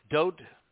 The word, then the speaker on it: Dode: So returning to the 89th Mizmor Dode song, the most pertinent piece of literature probably ever written, we find that Dode, and Dode is the correct pronunciation of the name that is known by most people as David. And it is Dode because there are only three letters in his name.